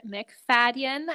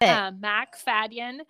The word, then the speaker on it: MacFadyen: Heather MacFadyen. I think I said that right. – You did it. – MacFadyen.